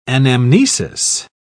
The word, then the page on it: anamnesis: British English